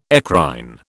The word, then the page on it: eccrine: British English